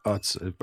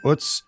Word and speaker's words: Utz: I've pronounced it wrong on this podcast in the past. It's not Utz. I've learned it's Utz.